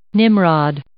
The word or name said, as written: nimrod